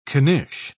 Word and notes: knish: British English

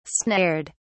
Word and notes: snared: British English

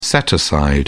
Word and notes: set-aside: British English

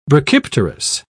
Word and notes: brachypterous: British English

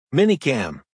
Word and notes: minicam: American English

